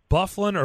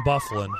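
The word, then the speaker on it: Byfuglien: Do you pronounce it Bufflin or Bifuglian? No, all kidding aside, Dustin Bufflin.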